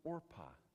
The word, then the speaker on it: Orpah: Orpah, just a little cultural side note, Oprah of today was supposed to be named Orpah, and they mispronounced it, so she stuck with the name Oprah.